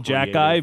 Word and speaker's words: Xhekaj: yeah, That's and so we are saying Jack Eye, but just so you know, it's spelled X H E K A J.